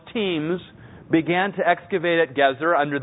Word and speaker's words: Gezer: He said, I predict that the individuals who excavate the city of Gezer, that's how I pronounce this word, not geezer, that has other connotations. So, Gezer,